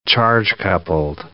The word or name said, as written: charge-coupled device